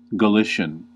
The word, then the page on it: Galician: American English; /ɡəˈlɪs.i.ən/; /ɡə.ˈlɪʃ.ən/; /ɡə.ˈlɪs.jən/; /ɡə.ˈlɪʃ.ɪən/